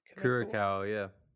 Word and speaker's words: Curaçao: He plays for Curaçao. Is that how you pronounce it? – Curaçao, yeah.